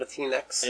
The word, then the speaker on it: Latinx: So we do that with Latinx, and the first place was is that how you say it, Latinx? – Latinx, okay.